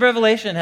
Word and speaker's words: Revelation: This is from the book of the Revelation, not Revelations, with an S on the end. It's the most common mispronunciation of the book, the Revelation.